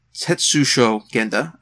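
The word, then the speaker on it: Tesshō Genda: There is Ryu, who is basically the de facto head of the group, voiced by Tetsuo Genda, I believe Tetsusho Genda, I think it's actually properly pronounced.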